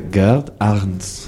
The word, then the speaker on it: Gerd Arntz: pronúnciame Gerd Anth, como se dice ahí abajo. ¿Abajo del todo? – Ah, vale. Gerd Arndt. – Claro, pues eso. – Escrito, para el que le interese, GERD ARNTZ.